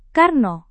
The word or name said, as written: karno